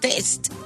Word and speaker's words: Taste: Como dijo una reportera de Caracol el otro día, Taste Atlas dijo taste. Que... – Ajá. – Pero es, ¿cómo se pronuncia? Taste.